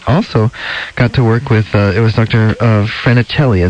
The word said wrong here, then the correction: Falcinelli: is that how you say it? – Falcinelli. – Falcinelli, okay.